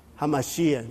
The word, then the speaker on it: HaMashiach: It's Yeshua Mashiach, the anointed one. HaMashiach. In fact, that's the right way to say, the anointed one.